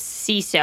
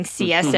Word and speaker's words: CISO: Oh, and thank you for clarifying how to pronounce CISO. I was saying CSO, mm-hmm.